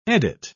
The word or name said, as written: edit